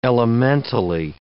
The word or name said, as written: elementally